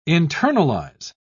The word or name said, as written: internalize